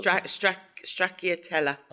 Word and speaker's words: Stracciatella: Stracciatella.